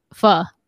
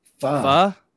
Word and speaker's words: pho: By the way, is it pho or pho, by the way? – How do you pronounce that word? Because somebody was in the comments saying we were pronouncing it wrong all night. – Oh, really? Oh, it's pho. – Fuh.